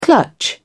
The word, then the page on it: clutch: British English